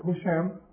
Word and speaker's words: Hisham: See, Zuhri was sitting with Hisham ibn Abd al-Malik, or Husham, that's correct pronunciation.